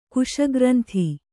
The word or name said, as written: ಕುಶಗ್ರಂಥಿ